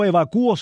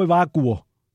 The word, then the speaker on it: evacuo: No es evacúe, es evacue. Ese acento es un disparate. Así que recuérdenlo ustedes. No se dice yo evacúo, sino yo evacuo.